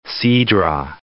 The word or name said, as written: Cidra